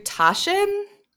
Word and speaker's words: Taschen: Yeah, I bought it through Taschen, I believe it's pronounced T-A-S-C-H-E-N.